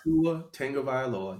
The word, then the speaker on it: Tua Tagovailoa: Tua Tagovailoa. Yeah, I said the name right. I've been practicing.